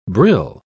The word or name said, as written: brill